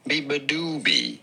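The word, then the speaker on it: beabadoobee: Bibadoobie. You do want to stress on the third syllable, the do syllable.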